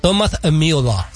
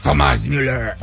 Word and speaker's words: Thomas Müller: Es que debe ser Thom, como Thomas. – Thomas Müller. – Ya, es que es alemán, acuérdate.